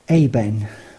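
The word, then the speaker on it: aben (אבן): Stone in the Hebrew of the Old Testament is pronounced aben, and in the Greek of the New Testament is pronounced lithos.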